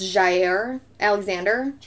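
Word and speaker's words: Jaire Alexander: I'm assuming this is pronounced Jair Alexander. – Yeah.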